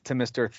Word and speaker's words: Thom: I'm going to need you to show more respect to Mr. Thom York. – Thom. – I know that it's Tom, but it's spelled T-H-O-M because I think it's so pretentious.